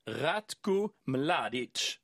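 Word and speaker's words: Ratko Mladić: Alltså, Ratko Mladic har hon inga problem med att uttala. Nej. Ett M framför ett L, inga krångligheter. Att uttala ett C som ett tsch är helt cool.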